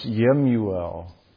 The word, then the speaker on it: Jemuel: And in verse 15 of the text, there's this name Jemuel, which is probably more accurately pronounced Yemuel.